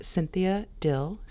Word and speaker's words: Cynthia Dill: Yes, my name is Cynthia Dill, CYNTHIADILL.